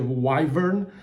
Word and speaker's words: Wyvern: I zainteresował mnie dzisiaj startup, no właśnie, nie wiem jak wymienić jego nazwę, wy wymówicie go nazwę Wyvern, pisze się W-Y-V-E-R-N, dla tych, którzy chcieliby sobie o nim poczytać.